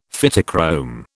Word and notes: Phytochrome: British English

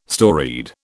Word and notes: storied: British English

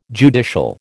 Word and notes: judicial: American English

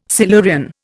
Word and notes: Silurian: Indian

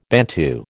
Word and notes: Bantu: American English

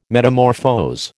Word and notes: metamorphose: American English

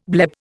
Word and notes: bleb: Indian

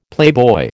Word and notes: playboy: American English

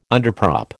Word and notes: underprop: American English

